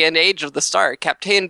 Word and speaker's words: Kapteyn: named Captain Star, which is spelled K-A-P-T-E-Y-N. Captain.